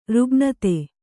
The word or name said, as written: ರುಗ್ಣತೆ